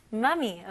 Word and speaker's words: mummy: 네, 발음은 아주 살짝 차이점이 있어요. 엄마라고 할 때 귀엽게 사랑스럽게 mommy라고 하고요. 여기서는 mummy. mummy.